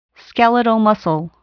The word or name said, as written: skeletal muscle